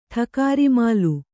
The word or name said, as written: ಠಕಾರಿಮಾಲು